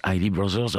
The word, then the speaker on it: Isley Brothers: Bon, moi j'ai longtemps appelé ça les Isley Brothers, mais bon, visiblement en américain, ça se prononce Isley Brothers.